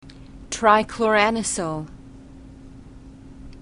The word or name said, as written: trichloranisole